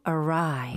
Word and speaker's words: awry: Go awry. Go awry. 스펠링도 주의하셔야 돼요. 이거는 눈으로 보시는 right. 게 좋은데요. 45페이지에 A-W-R-Y. 아하. Go awry. – 이거 잘못 보면 awry인가? 라고 아니면 awry인가? 라고 엉뚱하게 읽을 수 있을 것 같아요.